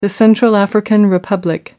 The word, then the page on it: central african republic: American English